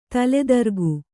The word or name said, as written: ತಲೆತಿರುಕ